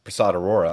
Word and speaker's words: Prasad Arora: Well, this month you've got another new character that you've got your hand in creating, which is Prasad Arora.